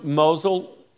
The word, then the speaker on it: Mosul: Yeah, so Mosul.